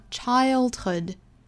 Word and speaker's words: childhood: 这个 ill 这个音其实就是由 I 这个双元音尾部的 a 去带后面的舌侧音发出来的 ill，所以是 child，后面再加 hood，这个音呢不能发成 hood，应该发成 hood，跟我们前天讲到的 took 是一样的发音，就是 took，hood。 Hood，还要注意这两个凑在一起呢，重音位置是在第一个音节，所以发 childhood。